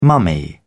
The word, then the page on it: mummy: British English